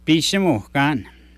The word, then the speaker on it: Pisimukan: In Michif, how do you say clock? – Pisimukan.